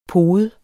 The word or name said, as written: pode